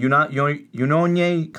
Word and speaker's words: Unione: Unione Calcio, which is football union.